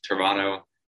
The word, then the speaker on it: Toronto: If you're from Toronto, you never pronounce the second T.